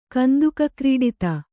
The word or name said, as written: ಕಂದುಕಕ್ರೀಡಿತ